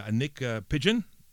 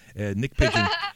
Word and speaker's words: Niyc Pidgeon: Now, Nick Pigeon, I must have mispronounced her name earlier, Nick Pigeon.